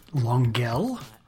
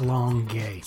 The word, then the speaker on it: Longueuil: Now, I've heard many Anglos call it Longueuil, but it's actually pronounced Longueuil.